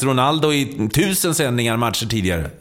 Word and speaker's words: Ronaldo: Ja, men alltså, vi har en kollega som fick nys på att den gamle Ronaldo, på portugisiska ska uttalas Ronaldo.